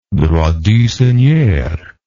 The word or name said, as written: droit du seigneur